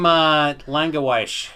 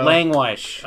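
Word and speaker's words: Langewiesche: William Langewisch. – There you go. – Langewisch. – Okay. – Sorry, William, if you're listening. – Langewisch, I know.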